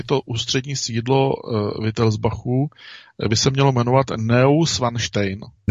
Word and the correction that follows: Neuschwanstein: Ne. Neuschwanstein. Ne. Neuschwanstein. – Jo, tak Neuschwanstein.